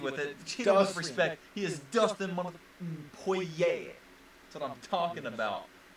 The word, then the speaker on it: Poirier: Poirier, by the way. It's not Poirier, it's Poirier. – However you want to pronounce Poirier. It. Poirier. – Poirier. – We make our pronunciations. – Poirier. It's Poirier. It's Poirier. – It's a French. It's French origin, people.